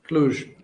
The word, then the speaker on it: Cluj: Am I pronouncing that correctly? Cluj? – Cluj. – Cluj. – With a J. – Cluj with a J.